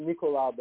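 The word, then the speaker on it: Nicolas: It's Nicolau, but it's spelled the same way. – Oh, okay. – So it's N-I-C-O-L-A-S. B-A-D-I-L-A, Nicola Badila on Instagram.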